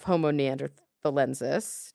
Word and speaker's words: Homo neanderthalensis: So he is not the type specimen of Homo neanderthalensis.